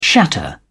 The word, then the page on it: shatter: British English